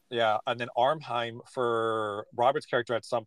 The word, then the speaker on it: Ephraim: The, and I think that Willem pronounces it Armheim is how it sounds, but it's mm-hmm. it's E P H R A I M.